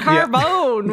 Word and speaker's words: carbone: Yeah, carbone, with the emphasis on the bone,